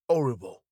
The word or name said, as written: horrible